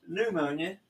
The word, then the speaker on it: pneumonia: Oh, that's pneumonia, not pneumonia. The P is silent.